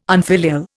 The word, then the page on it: unfilial: Indian